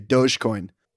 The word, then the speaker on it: Dogecoin: I'm going to call it Dogecoin, because that's, I think, what it is instead of Dogecoin. – I'm still Doge. Soft G, Dogecoin.